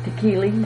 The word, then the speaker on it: Tekeli: You want to spell that name, can you? – T-E-K-E-L-I. – And that's, you're saying it, huh? – Tekili. – Tekili, and her first name? – Yüksel. Y-U-K E-L, I think.